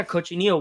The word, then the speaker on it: cochinillo: Yeah, cochinillo.